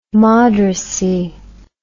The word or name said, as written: moderacy